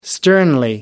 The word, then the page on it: sternly: American English